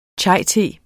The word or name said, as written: chaite